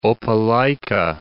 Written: Opelika

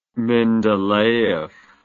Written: mendeleev

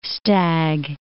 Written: stag

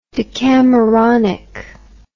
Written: decameronic

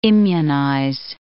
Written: immunize

